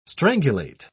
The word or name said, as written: strangulate